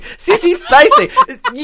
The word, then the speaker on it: Spacek: So then we get this great scene of Sissy Space. I can't speak. Sissy Spacek. You can't say her name, not in the list. Sissy Spacek. There we go.